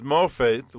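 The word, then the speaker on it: Mopheth (מופת): One is translated as sign, called Ot, phonetically spelled O-T-H, and then wonder, which is Mopheth, which is spelled M-O-P-H-E-T-H. And I found it interesting, Mopheth. That's probably not what they intended, but that's the way it came out.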